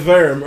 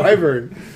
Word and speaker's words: Wyvern: And it was the Verm or whatever. – It was so weird. We were like, what word is he saying? And then we looked at the girls and were like, oh. Wyvern.